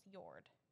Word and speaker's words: Jord: Yord. J-O-R-D is pronounced Yord.